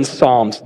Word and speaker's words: Psalms: Psalms. Now, a little word to the wise, it starts with a P. The P is silent.